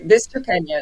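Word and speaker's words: Vischoqueña: It took me several Bolivian producers going, no, Vichocaina. Okay, Vichocaina. It's hard to pronounce, but it is, yeah, it is a very unique grape variety.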